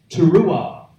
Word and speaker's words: teruah: a particular word pronounced teruah, T-E-R-U-A-H, teruah.